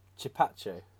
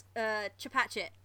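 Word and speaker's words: Chepachet: I'm going to say Chipache. – Almost. – Oh! – Almost. Very, very close. It's Chipachet. – Ah! More emphasis on the T. Chipachet.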